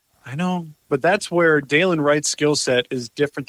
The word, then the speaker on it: Dylan: It's spelled D-Y-L-A-N. So you think Dylan Wright. It's Dalen, something I was corrected of. So it's Dalen Wright.